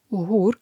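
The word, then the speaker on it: HUR: The main Defense Intelligence Directorate of the Ministry of Defense of Ukraine, UHUR, that's how you say it in Ukrainian, confirmed that Russian forces captured a Ukrainian commando in Crimea on October 4th.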